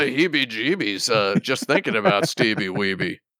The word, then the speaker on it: Wiebe: Oh, Steve Wiebe? – Yeah, Steve Wiebe.